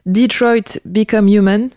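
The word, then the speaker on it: Detroit: Become Human: Tu as aussi fait des cascades qui ont servi dans un jeu vidéo, Detroit Become Human, je le dis bien? – Ouais.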